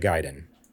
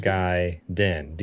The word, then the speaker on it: Gaiden: it is Ninja Gaiden. – There we go. – Aha. – So you were close. – Ninja Guy Din. D-N. Just Din. So there you go. Google says so.